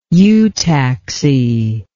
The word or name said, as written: eutaxy